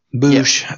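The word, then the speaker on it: Boushh: It's spelled B-O-U-S-H-H. I always thought it was B-O-S-H-H-O. – Boosh. Yeah. Got to see Boosh in Solo, and for some reason was in Lando's ship.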